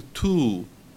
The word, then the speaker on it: too: Y se pronuncia casi como el too del saber y que se ponía con infinitivo, pero al escribir se pone con T doble O, ¿ok? Too, ¿ok? Se alarga un poquito más esa U.